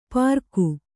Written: ಪ್ರಾಕು

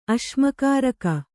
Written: ಅಶ್ಮಕಾರಕ

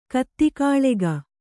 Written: ಕುದುಕುಳಿಗ

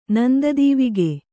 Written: ನಂದದೀವಿಗೆ

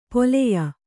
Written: ಪಲ್ಯ

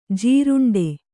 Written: ಜೀರುಂಡೆ

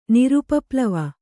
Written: ನಿರುಪಪ್ಲವ

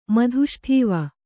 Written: ಮಧುಷ್ಠೀವ

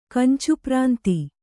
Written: ಕಂಚುಪ್ರಾಂತಿ

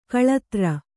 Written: ಕಳಾಧರ

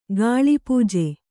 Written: ಕಲ್ಪಜ

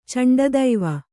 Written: ಚಂಡದೈವ

